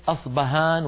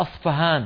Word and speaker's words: أصفهان: أصفهان بالفاء، لماذا؟ وهل أصبهان بالباء غير أصفهان بالفاء؟ لا، هي نفس المدينة، هي المدينة نفسها، ولكن نطقها بلغة الفرس أصفهان فهان، يعني حرف محير بين الباء والفاء،